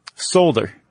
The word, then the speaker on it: solder: A solder. You got to say that L. – I think the L silent, unless it's regional.